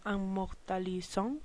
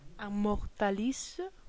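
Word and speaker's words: immortalisent: Comment se prononce le mot immortalisant? – Non. Immortalise. On ne prononce pas E N T parce que c'est un verbe. – Immortalise.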